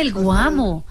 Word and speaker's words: el Guamo: Entonces, que Bésame, el Guamo.